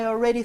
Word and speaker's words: already: Oh no, I already threw out the box. 맞는지 볼게요. Okay. 자, 일단은 already라는 것도 여러 번 반복을 했었죠. 발음할 때 already 아니라고 했습니다. A-L, A-L-L. 이런 스펠링은 all보다는 입 모양을 벌려주셔야 돼요.